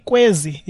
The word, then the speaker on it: Kwezi: So how do you come up with is it Kwezi? Am I saying it right? – That's right. That's Kwezi. – Kwezi.